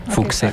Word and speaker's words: Fouché: También, si gustan escuchar la música en plataformas de streaming, están todas esas, busquen la palabra Fouché, pero con acento en la E para que lo encuentren. – Fouché.